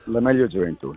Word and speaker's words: La Meglio Gioventù: This is La Meglio Gioventù. Am I getting it roughly, pronunciation? – La Meglio Gioventù, yeah.